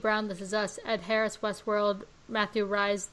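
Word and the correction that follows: Rhys: the Reese, that's Reese. – Um, yes.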